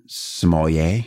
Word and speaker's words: sommelier: So you also have to become a sommelier. Am I saying that right? – Yes, you are.